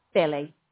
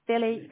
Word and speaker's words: Billie: Billy. Billy Kern, which is B-I-L-L-I-E.